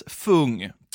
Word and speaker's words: Phung: I många av de här fallen ska det nog uttalas fung. P-H-U-N-G.